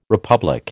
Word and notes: republic: American English

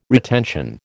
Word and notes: retention: American English

